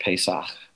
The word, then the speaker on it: Pesach: In, in Hebrew, it's pronounced Pesach, Passover.